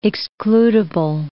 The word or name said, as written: excludable